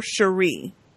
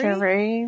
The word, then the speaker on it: Cherie: Oh, I don't know if it's pronounced Cherry or Cherie. I think it's C-H-E-R-I-E. – Cherie or Cherry? – Cherie, maybe? Cherie? – I think Cherie. Probably Cherie.